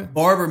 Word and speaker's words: Barber: Barber, man.